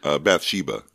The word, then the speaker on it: Bathsheba: the one person that is obviously the biggest star of the film would be Georgina Spelvin, who plays the role of, is it Bathsheba? – Bathsheba. – Bathsheba? – Yeah, that's how we've always pronounced it, okay.